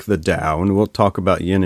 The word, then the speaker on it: Tao: So it's Tao, T-A-O, pronounced Tao.